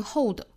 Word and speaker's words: hold: hold hold 舌头没有动态大家仔细听我发音的区别啊一个是有舌侧音的一个是没有舌侧音的 hold hold 第二种 hold hold 大家是能够听出来区别的对吧所以说我们在发音的时候也是要把这个舌侧音发出来舌尖去抵上齿龈发 u 的发音